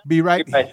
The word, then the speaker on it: B. Reith: I can never get his name right. – B. Reith.